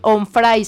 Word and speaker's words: Onfray: pero se llama Michel Onfray, se escribe. A ver, ¿tú sí pronuncias? – Onfray. – Onfray, qué bonito.